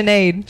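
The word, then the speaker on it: Sinéad: She said it better than I did, though. Cinead. – Wait, it's spelled like that? What did you say? – Sinead. – Sinead. I think it's like an Irish name.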